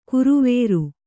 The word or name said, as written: ಗರವರ